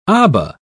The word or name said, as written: aba